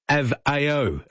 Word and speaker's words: Aveo: Aveo is pronounced Av, as in have, A-O. Av-A-O is how you pronounce it.